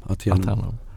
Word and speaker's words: Aternum: Jag kan inte uttala det. Attendum? Attendum? Aternum. – Aternum.